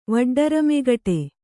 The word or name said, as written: ವಡ್ಡರಮೆಗಟೆ